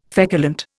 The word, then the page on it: feculent: Indian